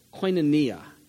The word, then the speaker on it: koinonia: The Greek word for fellowship, I believe the pronunciation in the transliteration is koinonia, K-O-I-N-O-N-I-A, koinonia. It's actually pronounced E-A, not I-A at the end.